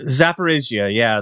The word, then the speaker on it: Zaporizhzhia: Is it pronounced Zaporizhia? – Zaporizhia, The nuclear power plant in Zaporizhia Oblast, which is the largest nuclear power plant.